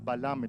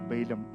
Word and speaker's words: Balaam: Because sometimes, you know, after giving the full 2 hours talk, some people will come and say, Pastor, it was not Balaam, it was Balaam. Okay, Balaam. Just a Malayali pronunciation in the Malayalam Bible.